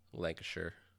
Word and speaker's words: Lancashire: Or, yeah, anything with shire is sure. – Yeah. – Lancashire.